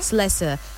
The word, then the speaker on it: Slessor: I hope I'm pronouncing that Slessor. – Name. Slessor.